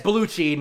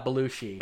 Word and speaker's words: Baluchi: Yeah, it's Baluchi, not Belushi.